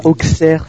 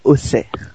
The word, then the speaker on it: Auxerre: C'est pas Auxerre, c'est Auxerre. – Oui, je sais. Depuis tout à l'heure, j'ai galère. – Très attaché à la prononciation paysanne, donc on va garder les valeurs prolétaires, c'est Auxerre et pas Auxerre.